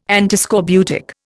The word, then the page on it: Antiscorbutic: Indian